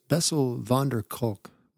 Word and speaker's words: Bessel van der Kolk: I believe it's Bessel van der Kolk